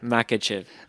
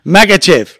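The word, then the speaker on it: Makhachev: Makachakov, isn't it? How do you say it? – Makachev. – Makachev.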